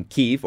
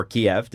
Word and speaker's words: Kyiv: He's born in Lagos, spent years of his youth in Kyiv, or Kiev, depending upon how you pronounce it, based on the impeachment hearings. Everybody says Kyiv in the States, but in Canada, we call it Kiev.